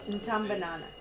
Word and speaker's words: Ntambanana: It is spelled like this, N for Nelly, T-A-M for Mary, B-A-N-A-N-A. So you're close. Ntambanana. Okay, you got it. That's it. – B-A-N-A. – B-A-N-A-N-A. N-T-A-M for Mary, B-A-N-A-N-A.